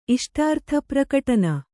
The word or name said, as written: ಇಷ್ಟಾರ್ಥಪ್ರಕಟನ